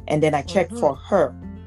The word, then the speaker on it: Hur: So the two people, the two people that were holding up the hands of Moses were Hur, H-U-R, that's the way I can pronounce it, Hur and Aaron.